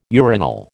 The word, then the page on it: urinal: American English